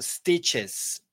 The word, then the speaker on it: stitches: Stitches. Stitches. It's easy. Stitches, es una I corta, Jacqueline, como si fuera más una E que una I, pero una E muy suave, stitches.